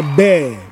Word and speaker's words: bear: And then the animal is pronounced bear. Bear.